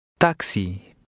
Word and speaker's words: táxi: In number 15, the X is pronounced as in the corresponding English word. Try to read number 15. – Táxi.